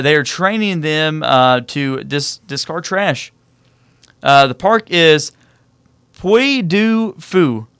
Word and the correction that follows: Puy du Fou: I'm sure I completely butchered that. – Puy du Fou. – Puy du Fou. There you go. That's a better accent.